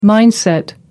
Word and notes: mindset: American English

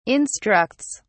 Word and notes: instructs: British English

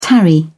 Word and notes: tarry: British English

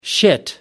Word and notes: shit: American English

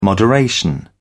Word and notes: moderation: British English

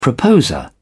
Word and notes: proposer: British English

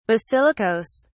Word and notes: basilicas: British English

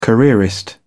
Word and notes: careerist: British English

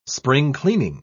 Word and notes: spring-cleaning: British English

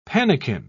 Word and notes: pannikin: British English

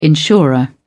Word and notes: insurer: British English

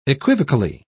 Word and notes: equivocally: British English

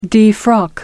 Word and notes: defrock: American English

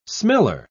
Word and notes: smeller: British English

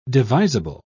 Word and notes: devisable: British English

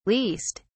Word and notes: leased: British English

